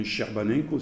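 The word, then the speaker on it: Scerbanenco: Je vous recommande vraiment, c'est écrit S-C-E, on prononce en italien Sherbanenko, c'est-à-dire S-Serbanenko, il faut les prononcer à la française.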